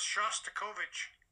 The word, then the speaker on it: Shostakovich: The only thought is his name is – pronounced Shostakovich.